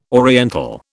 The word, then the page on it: oriental: British English